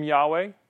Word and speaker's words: Yahweh: We can't pronounce that in our language, right? Like if somebody said, hey, YHWH, pronounce that, you'd be like, so we added the two vowels in it. That's where we get the name Yahweh.